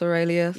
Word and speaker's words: Aurelius: Marcus Aurelius.